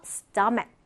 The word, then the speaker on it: stomach: 자, 지금 발음을 잘 보면은 크 발음으로 끝나요. 그쵸? 그래서 stomach, stomach 이렇게 발음을 해줍니다.